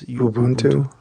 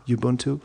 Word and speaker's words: Ubuntu: How do you pronounce Ubuntu? – Ubuntu.